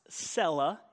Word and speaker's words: Selah: So I want you to know why I pronounce it Selah, because it's pronounced Selah in the Hebrew.